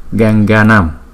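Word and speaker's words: Gaṅgānām: Cái chữ này không đọc là nên mà đọc là nam. Ganganam, bởi vì không có cái dấu gạch xéo và chúng ta không có nghỉ hơi ở đó.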